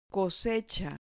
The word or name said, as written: cosecha